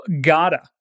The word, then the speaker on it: gotta: Acabei de me lembrar da redução extrema do gotta, cuja forma extensa seria have got to, e significa ter que fazer algo. Bem, embora gotta seja escrita com dois T's, ela é pronunciada com som de R.